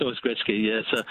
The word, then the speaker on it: Gretzky: Wayne Gretzky has always been pronounced Wayne Gretzky,